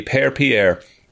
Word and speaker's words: Père: Also, in French, Father Pierre would be Père Pierre. That's how that would be pronounced.